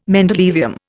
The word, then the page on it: mendelevium: Indian